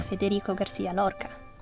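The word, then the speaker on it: Federico García Lorca: Federico Garcia Lorca. – I think that was admirable. – Go on, how would you say it? – Federico Garcia Lorca. It was basically the same.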